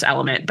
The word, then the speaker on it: LMNT: It's actually spelled L-M-N-T. I think it's pronounced Element, but anyways, they have amazing, like, I love the flavors that they have.